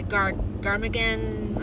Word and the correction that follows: Garmadon: Let's see how we say it here. But he Garmadon. That's it, Garmadon.